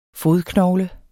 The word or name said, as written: fodknogle